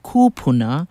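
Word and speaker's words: kūpuna: Kupuna, with a kahako or makron over the first U, pronounced kupuna, is a plural of kupuna, or grandparents.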